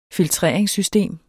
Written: filtreringssystem

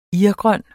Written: irgrøn

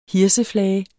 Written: hirseflage

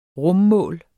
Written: rummål

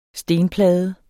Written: STENPLADE

